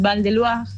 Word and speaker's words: Val de Loire: O sea, el, Blanc es del Val de Loire, se dice así, pero se escribe Valle de Loira.